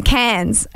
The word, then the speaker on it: Cairns: No, so we're up in, I'm going to keep saying it wrong, Cairns. I keep calling it Cairns, and people are like, you're going to Cairns?